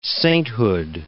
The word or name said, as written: sainthood